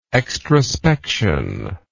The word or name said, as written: extrospection